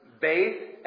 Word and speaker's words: Beit: And the thing that's interesting, in Hebrew, Bethlehem, it's pronounced, it's actually two words. It's two words brought together. It's Beit and Lechem. Beit is house of, Lechem is bread or life.